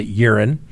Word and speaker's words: Euren: Now, urine... E-U-R-E-N, we're going to pronounce it urine.